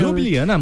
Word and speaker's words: Ljubljana: Falei certo? – Ljubljana, muito bem, nota 10.